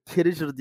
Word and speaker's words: Carragher: Sonra dedim ki Karagraher adama. Carragher diye okunuyorum.